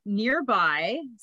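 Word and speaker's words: Nrby: It's nearby, so it's spelled NRBI, and I was You don't even want to hear what I thought it said.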